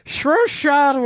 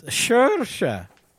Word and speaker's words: Saoirse: Saoirse Ronan won for Lady Bird, which I haven't seen yet. – By the way, that's how you pronounce her name.